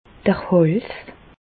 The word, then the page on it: d'r Hàls: Bas Rhin; Reichshoffen